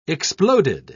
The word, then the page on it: exploded: British English